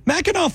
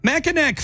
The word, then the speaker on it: Mackinac: Mackinac fudge is okay. Oh, wait. I'm sorry. I said it wrong. Mackinac fudge is okay.